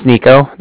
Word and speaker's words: Niko: My name's Nico. That's spelled N-I-K-O. And the Bank of Cardiff is spelled C-A-R-D-I-F-F, as in Frank, Frank.